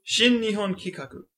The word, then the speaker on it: Shin Nihon Kikaku: Shin Nihon Kikaku? – Shin Nihon Kikaku.